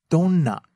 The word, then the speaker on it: どんな: And give us, you really want to hit the N in there. It's どんな? Because we have three syllables in there.